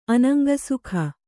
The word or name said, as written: ಅನಂಗಸುಖ